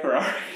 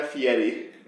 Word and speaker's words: Fieri: Yeah, how do you say it? – Guy Fieri. – Fieri. – Yeah, you pronounce it sort of like a T and D kind of a thing instead of the R.